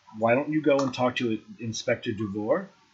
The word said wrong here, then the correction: DuVoe: Or DuVot? What did I say his name was? DuWhat? – DuVoe. – Inspector DuVoe, and he can help you.